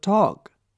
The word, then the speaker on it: talk: Look at the word talk. T-A-L-K. Here L is silent.